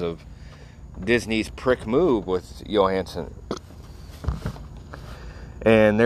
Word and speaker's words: Johansson: Listeners, everybody wants to say Johansson, but the J is pronounced with a Y, so it's Scarlett Johansson.